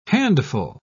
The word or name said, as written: handful